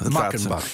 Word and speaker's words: Meckenbach: Ook op 2 januari postte hij een foto waarop kernfysicus Ralph Meckenbeck, zeg ik het goed? Of is het Meckenbach? – Ik geloof dat. – Meckenbach, ja.